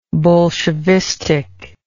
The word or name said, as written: bolshevistically